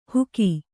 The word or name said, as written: ಹುಗಿ